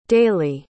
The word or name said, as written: daly